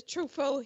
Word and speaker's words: Truffaut: Francois Truffaut. Did I say that right? – Truffaut.